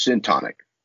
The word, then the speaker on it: syntonic: What do psychologists mean when they talk about dystonic versus syntonic? – Beautiful.